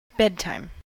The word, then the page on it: bedtime: American English